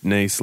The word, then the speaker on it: Nice: Yeah, Arnott's have come out, officially said that it is pronounced Nice, like the French city.